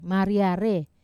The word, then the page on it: maria re: Wamesa